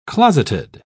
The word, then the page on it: closeted: British English